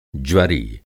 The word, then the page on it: ज्वरी: Hindi